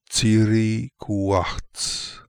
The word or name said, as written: Tsirikooakhtz